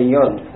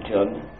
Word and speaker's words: Yod: Now, this Yod letter, if we observe in German, as in Hebrew, you pronounce the letter J as if it were an English letter Y. You say Yod for that, not Jod.